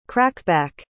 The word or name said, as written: crackback